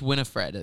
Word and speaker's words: Winifred: Winfred, Sarah, Winfred. And Mary. I don't think Winfred. I would have pulled that out. – No, it's Winifred, I think. – It's Winifred.